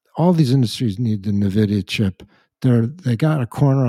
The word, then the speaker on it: NVIDIA: Stock tip number three, and I know there's one listener out there who's going to make fun of me because I won't pronounce this right, but NVIDIA? – You nailed it.